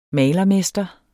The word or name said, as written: malermester